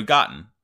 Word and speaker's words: Goten: This guy's name is Goten, G-O-T-T-N, I believe. – I was specifically listening for how they say it, and it's kind of like cotton, but with a G. – Okay, yeah, so Goten.